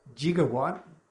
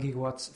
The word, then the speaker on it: gigawatt: One, gigawatt, that actually is an acceptable pronunciation of gigawatt.